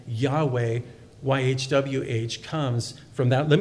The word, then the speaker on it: Yahweh: And it's pronounced Yahweh probably most accurately and probably most popularly in the past, at least, it was Jehovah as it was pronounced.